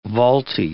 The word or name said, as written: vaulty